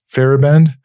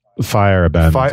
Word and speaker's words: Feyerabend: I said Feyerabend? – It's Feyerabend.